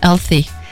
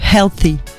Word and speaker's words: healthy: Healthy, pardon. Je l'ai bien prononcé ça va, ça va. Le petit H au début. Healthy. Healthy. Alors, toutes ces sources proposent des milliers d'informations sur le sujet, mais elles ne sont pas toujours fiables.